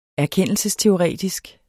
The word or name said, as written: erkendelsesteoretisk